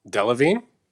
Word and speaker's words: Delevingne: I always say her name wrong. – Delevingne? – There you go.